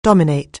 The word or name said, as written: dominate